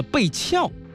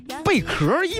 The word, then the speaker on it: 贝壳: 周迅老师读成了贝翘，这我不知道这因为什么他读成贝翘啊。一般来说是甲翘、地翘、躯翘、金蝉脱壳才会这么读啊。所以这个贝壳一般不会读错，因为这个字读错了呢，为了避免大家进一步讨论的升级，音乐平台你去搜搜看，这个歌的歌词已经改成了贝角啊。